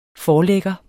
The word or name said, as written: forlægger